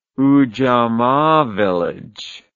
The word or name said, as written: ujamaa village